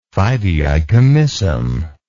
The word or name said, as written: fideicommissum